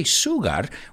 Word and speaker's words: sugar: Let's look at that word, sugar. It's an interesting word because, well, it doesn't have an SH in it, but we pronounce it as if there were an SH in it. So we don't say sugar, we say sugar, sugar.